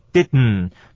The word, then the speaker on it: didn't: 那个第二个 d 不能念，我们做 didn't，这个声音呢就在喉咙里面发音，我们念做 didn't，所以其实很难听见的，比那个 did 呢 did 还要更难听见，有一个嗯的那个啊鼻音的感觉了。